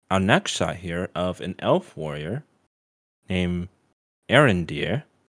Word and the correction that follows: Arondir: or maybe I pronounced it wrong, Arondir. That actually sounds like it makes more sense.